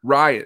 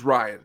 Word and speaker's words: Rian: You know, how do you pronounce it again one more time and I'll never mess it up? – Rian. – It's just Rian. Okay. Just Rian. – It's an Irish way of spelling Rian.